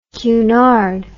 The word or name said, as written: Cunard